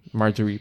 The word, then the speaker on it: Margaery: Sim, Margaery, pronto. – Aqui é soft G, não é hard G, é soft G.